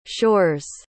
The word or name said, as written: shore's